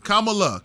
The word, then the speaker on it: Kamala: So to Kamala, however the fuck you pronounce Kamala. – Her name. – Kamala. I told you it's that meme Kamala. That says, it says comma, Kamala. Like the word comma, and then LA. – Okay. Kamala, Los Angeles.